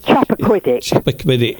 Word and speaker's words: Chappaquiddick: Chappaquiddick incident.